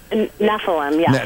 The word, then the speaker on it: Nephilim: Nephilim, yeah.